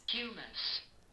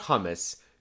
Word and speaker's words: humus: So not hummus. Humus. But well, still, it looks an awful... – H-U-M-U-S. – It looks an awful lot like hummus, ladies and gentlemen. – So you just don't pronounce the H. – No, you do. You just pronounce it with a long U.